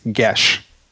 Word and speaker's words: geas: The correct spelling of, or the correct pronunciation, from what I understand, of G-E-A-S is gesh.